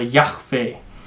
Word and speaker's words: Yahweh: 那很多的经外的文学翻译成中文会把这个词翻译成亚威，因为在这个希伯来文里面的发音里面配上元音，这个叫做 Yahweh，那很多的时候会被翻译成亚威，但是中文的圣经里面一般被翻译成耶和华。